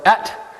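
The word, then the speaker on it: et (את): So, this, whenever I see the Hebrew term Aleph and Tav, which is pronounced et in the Torah,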